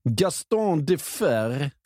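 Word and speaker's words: Gaston Defferre: Gaston Defer. – Precis, jag tror du ska känna dig stolt över uttalet också. Det kändes trovärdigt.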